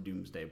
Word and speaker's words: Domesday: So the first thing I need to cover about the Doomsday book is the spelling of the Doomsday book. So yes, the Doomsday book is spelled Domesday because it comes from Middle English, and the direct translation to modern English is Dooms.